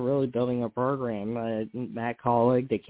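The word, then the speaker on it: Kaulig: Well, you know, certainly calling racing, how do you spell that? K-A-U-L, colleague? Did I say that right? Colleague racing? Yeah, okay.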